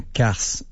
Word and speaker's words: karst: On est ce qu'on appelle un carse. C'est un nom à coucher dehors un petit peu, là, que jamais personne n'a entendu. – C'est la première fois. – Ça s'écrit K-A-R-S-T.